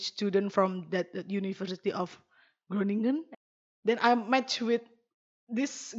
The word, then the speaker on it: Groningen: The internationals call it Groningen. I'm gonna try to pronounce it as the Dutch, but it's gonna be, I think, still way too far from it. But they call it Groningen.